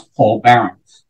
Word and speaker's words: Paul Baron: There aren't too many different ways for people to pronounce Paul Barron, which is my name.